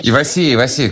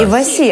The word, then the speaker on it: Иваси: Но вот у группы «Иваси», я не знаю, куда делать ударение. – Иваси, как, правильно, как рыба. – Иваси, отлично.